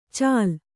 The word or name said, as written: ಚಾಲ್